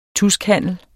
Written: TUSKHANDLEN

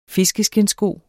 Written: fiskeskindssko

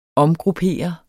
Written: omgruppere

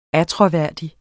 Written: attråværdig

